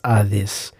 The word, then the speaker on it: Hades: Well, he was actually going to the underworld, as we might call it in English, also called Sheol in Hebrew and Hades in Greek. Although my more astute listeners of Greek will know that it's pronounced Hades.